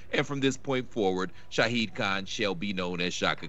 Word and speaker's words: Shahid Khan: Shaka Khan, a.k.a. Shahid Khan, owner of the Jaguars. – Well done. – Because I said it right for once.